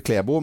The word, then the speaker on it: Klæbo: Klæbo husker vi jo. Ja. – Jeg trodde egentlig at For meg så uttales det Klebo, men det kan jeg kanskje ikke begynne å blande meg inn i.